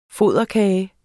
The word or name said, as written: FODERKAGE